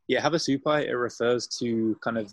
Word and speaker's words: Havasupai: Havasupai.